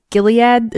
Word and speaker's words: Gilead: Next headline, we got Gilead. Is that how you say it? Gilead Sciences? – Yeah, you got it.